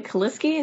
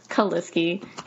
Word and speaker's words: Kaliski: It's going to be Julia Kaliski. It's just my name. Super simple. It's spelled funny. J-U-L-E-A-H, Julia Kaliski, K-A-L-I-S-K-I.